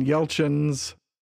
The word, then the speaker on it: Yelchin: We'll come back and we're going to talk about Stephen Sommers, Anton Yelchin's, I'm afraid I'm pronouncing that wrong. – No, I think you got it right.